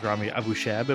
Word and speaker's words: Abushhab: My guest today is Rami Abushhab. Am I pronouncing that right? – Abushhab, yeah. – Abushhab. Okay, I'll get that right.